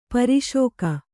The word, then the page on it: ಪಾರಶಿಕ: pāraśika; kannada